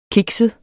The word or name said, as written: kikset